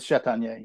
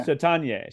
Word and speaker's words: Chatagnier: It was Chatagnier. Yeah. Chatagnier.